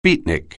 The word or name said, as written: beatnik